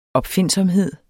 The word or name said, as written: opfindsomhed